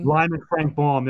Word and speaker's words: Lyman Frank Baum: And also, I want to point out that our friend Lyman Frank Baum, is that how you say his name? – Lyman Frank Baum, that's it.